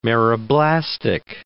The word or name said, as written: meroblastic